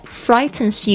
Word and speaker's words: frighten: 好的，今天的变身日记的主题是 The thing that frightens you, 让你害怕的事情。 The thing That frightens you. Frighten, F-R-I-G-H-T-E-N-S, 这个 G-H 没有发音， frighten, frightens, 就是说这一件事情，所以它加上 S, 在这个修饰这个单数的主词。